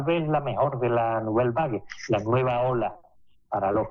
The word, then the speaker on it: Nouvelle Vague: Y ahora vamos con la película que más te llama a ti la atención de esta semana, que es un drama francés titulado Nouvelle Vague, que no sé si lo he dicho bien o no. – Sí, la Nouvelle... Ya, lo has dicho estupendamente.